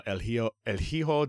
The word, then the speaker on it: El Hijo del Vikingo: You're almost there. Silent H. – El Hijo. – There you go. H's are silent and J's sound like H's.